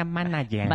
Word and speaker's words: manager: Se pronuncia manager. – Manager. – Exacto.